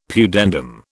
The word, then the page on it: pudendum: British English